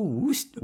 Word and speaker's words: Worcester: Oh, Worcester. – That's how you say it, right? Worcester? How do you say Worcester. – That? – It's Worcester. It's spelled Worcester, and it's pronounced Worcester.